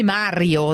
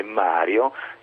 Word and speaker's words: Mario: Anche se l'accento è posto diversamente, cioè Maria e Mario, in realtà non c'entrano nulla.